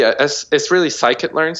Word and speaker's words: scikit-learn: Sklearn. How do you spell that? – It's really scikit-learn. So SCI like sci, and then kit, and then learn. – Okay. – KIT learn. Yeah.